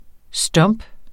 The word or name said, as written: stomp